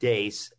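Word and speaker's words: Deace: It's pronounced Dace, as I learned in the interview.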